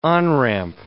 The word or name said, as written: on-ramp